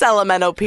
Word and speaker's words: LMNOP: Many people asked me that, and I was like, it's just LMNOP. It's just a chunk of the alphabet.